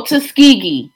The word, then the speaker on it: Tuskegee: Oh, you he just played, lost, you know, you just lost some black points by mispronouncing Tuskegee. – I know, Tuskegee.